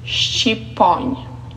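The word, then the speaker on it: shqiponjë: But how do you say the word eagle in Albanian? It's shqiponj. Shqiponj.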